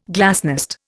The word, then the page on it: glasnost: Indian